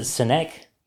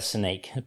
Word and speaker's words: Seneque: Some people pronounce it Seneque. – Seneque. Interesting. – But I prefer the Seneque pronunciation. – Yeah, no. – It just sounds a bit. – Sounds Seneque. – It sounds more French. It is Mauritian French.